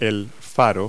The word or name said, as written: beacon